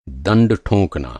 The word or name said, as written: ठोंकना